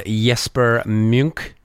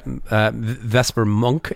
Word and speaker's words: Jesper Munk: This one features Jesper Munk. Yeah, I said that right. Vesper Monk, Jesper Munk.